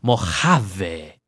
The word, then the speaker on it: Mojave: You know how to say it the right way. You got to let it roll a little. Mojave.